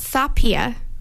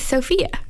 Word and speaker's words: Sofia: Well, because my name is not Sapia. It's Sofia.